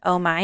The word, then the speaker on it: Oh Mai: Also, Oh My, that, oh, you guys, if you live in Utah and you have not been to Oh My, it's spelled O-H-M-A-I.